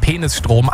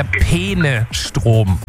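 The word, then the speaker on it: Peenestrom: Und es ist, also ich bin mir sicher, sie spricht es absichtlich so aus, weil sie sagt ja eindeutig Penisstrom, aber es geht um den Fluss Peene und es ist der Peene-Strom.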